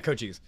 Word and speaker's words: Cochise: I mean, it's it's Kokais or Kochis, have you pronounce it. Yeah, Cochise.